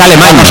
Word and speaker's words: Alemany: Por la pronuncia de mal, es Alemany.